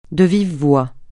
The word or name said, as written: de vive voix